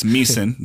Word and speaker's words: Meissen: And I was like, it's Meissen. The I is silent.